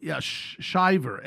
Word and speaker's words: Shiver: This Georgia couple, this individual, last name Shriver, and am I saying it right? Shriver, S-C, yeah, Shiver, S-H-I-V-E-R.